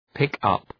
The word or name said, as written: pick-up